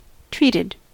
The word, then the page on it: treated: American English